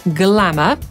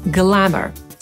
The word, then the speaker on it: glamour: Po brytyjsku wymówimy to słowo jako glamour, a Amerykanin powie glamour.